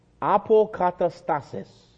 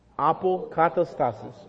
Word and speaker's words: Apokatastasis: I'm going to spell it for you, then I'll pronounce it. A-P-O, kata, K-A-T-A, stasis, S-T-A-S-I-S. Apokatastasis. Everybody say that together. Apokatastasis.